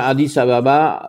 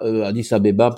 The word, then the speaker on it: Addis Abeba: il est à 3 degrés, enfin Addis Ababa, Addis Abeba, selon comment on le prononce, est à 3 degrés de latitude nord.